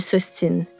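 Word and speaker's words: s'obstine: Lorsque la lettre B, se retrouve entre un O et un S, elle est muette. Donc, on ne prononce pas le B quand on conjugue obstiné en forme pronominale. On dit je m'ostine, tu t'ostines, il, elle s'ostine, mais on garde quand même le B pour le mot obstination.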